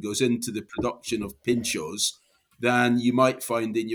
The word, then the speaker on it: pintxos: Pintxos, am I pronouncing that right? – Absolutely. – Pintxos, right, yeah.